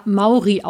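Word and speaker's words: Mowry: Tini hat mich darauf hingewiesen, dass die Designerin des Nightshift-Schals, Andrea Movri, wie ich sie genannt habe, aus dem amerikanischen oder aus dem US-amerikanischen Raum stammt und daher wahrscheinlich eher Andrea Mauri ausgesprochen wird.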